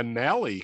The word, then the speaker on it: Nally: Yeah, it's the Nally Cars 500 or 250. It rhymes with Alley.